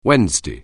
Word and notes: Wednesday: anglais

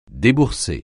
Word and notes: débourser: French

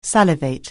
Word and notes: salivate: anglais

